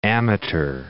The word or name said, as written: amateur